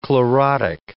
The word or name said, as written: chlorotic